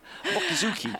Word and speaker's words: Mochizuki: But, you know, Nick Munro came here a few minutes ago, and he actually came up with a great stat about Mochizuki. Do I say it right? – Mochizuki? Well, not really, but it's close. Mochizuki.